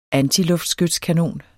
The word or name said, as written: antiskating